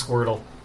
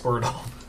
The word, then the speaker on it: Squirtle: I'm pretty sure it's Squirtle. I think you're pronouncing that wrong. It's pronounced Squirtle.